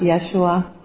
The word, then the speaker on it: Yeshua: And so the Hebrew name and the Aramaic name is pronounced Yeshua.